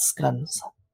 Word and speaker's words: scones: And don't forget English scones and pastries, although the English would have pronounced that scones.